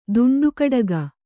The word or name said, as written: ದುಂಡುಕಡಗ